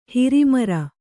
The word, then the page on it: ಹೊರಮಾರು: haramāru